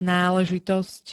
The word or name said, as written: náležitosť